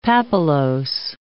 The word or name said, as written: papillose